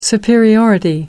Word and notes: superiority: American English

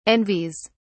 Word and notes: envies: British English

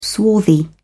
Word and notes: swarthy: British English

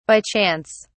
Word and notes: by chance: British English

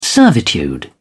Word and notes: servitude: British English

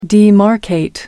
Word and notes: demarcate: American English

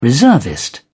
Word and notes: reservist: British English

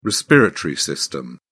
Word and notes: respiratory system: British English

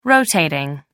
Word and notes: rotating: American English

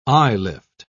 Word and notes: eyelift: British English

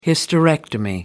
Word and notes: hysterectomy: American English

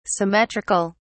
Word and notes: symmetric(al): British English

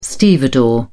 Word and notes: stevedore: British English

